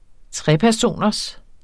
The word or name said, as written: trepersoners